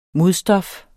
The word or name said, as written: modstof